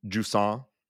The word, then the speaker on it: Jusant: Yeah, so we did look up the pronunciation Jusant. That is the French way to say it, or at least that's our best attempt at it.